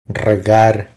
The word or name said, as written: Regar